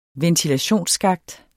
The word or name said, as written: ventilationsskakt